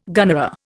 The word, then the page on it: gunnera: Indian